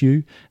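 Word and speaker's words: Huw: I think it's actually Hugh, not How, but when we originally pronounced his name, H-U-W, I think it's Hugh.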